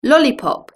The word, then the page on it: lollipop: British English